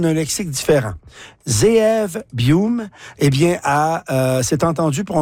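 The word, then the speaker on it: Buium: Les Canucks, qui donne 75 millions à Ziv Bouillouma.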